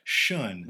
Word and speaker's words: Shun: How do you say that? – Shun. – Which means? – Shun means beautiful one. It's a kind of a play on the German word Shun. – Yeah. – So yes, that's the correct pronunciation.